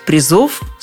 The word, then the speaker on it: призов: Это единственное число и ударение все время на корне – но вот призов становится больше. И обратите внимание, ударение с корня как ветром сдувает. Призы, призов, призам, призами, о призах.